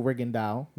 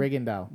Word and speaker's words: Rigondeaux: So it's Vasyl Lomachenko versus Guillermo Rigondeaux. So the names, you know. – Yeah. So Lomachenko, he's from the Ukraine. – Okay. – And Guillermo Rigondeaux, they just give a guess.